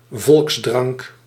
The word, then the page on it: volksdrank: volks·drank